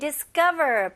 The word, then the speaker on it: discover: 대신 강세에 신경 좀 써주셨으면 좋겠어요. 그래서 discover가 아니고요. Discover, 강세가 어디에 들어가요? 네, ka에 들어가죠.